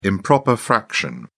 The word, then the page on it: improper fraction: British English